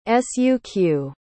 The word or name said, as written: suq